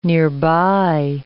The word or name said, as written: nearby